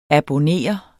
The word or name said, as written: abonnere